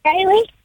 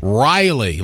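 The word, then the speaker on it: Rilee: Riley. – Kylie? – Riley. – Like Riley Green? – Yeah. – That's okay. Cool. Spelled different, though. You spell it with an R-Y? – No, it's R-I-L-E-E.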